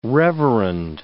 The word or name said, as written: reverend